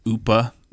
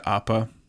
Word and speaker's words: Appa: Like Upa instead of Appa.